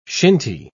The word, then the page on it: shinty: British English